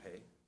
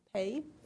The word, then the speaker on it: Peay: I don't know how to pronounce his last name. P-E-A-Y? – Pay. – Pay? Greg Pay.